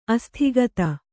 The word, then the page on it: ಅಸ್ತಗತಿ: astagati